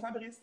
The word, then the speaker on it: Fabrice: Fait que l'erreur a été faite, puis moi, j'ai juste fait comme, by the way, say my name correctement, mon nom, c'est Fabrice.